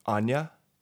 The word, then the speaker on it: Onua: Yeah, so K-I-N period. And then my new name is Jay Onua. So J-A-Y-O-N-U-A. It's really actually pronounced Anya. Like Anya.